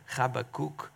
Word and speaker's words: Habakkuk: So last week we started to get into the answers that God gave to this prophet, Habakkuk. I know it's hard to pronounce his name, but that's how you pronounce it.